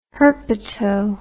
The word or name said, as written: herpeto